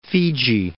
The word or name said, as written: Fiji